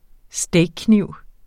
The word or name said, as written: steakkniv